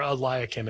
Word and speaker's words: Eliakim: Eliakim in Isaiah 22. And so, or Eliakim, as some are going to pronounce it,